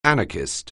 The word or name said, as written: anarchist